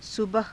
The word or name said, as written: subaha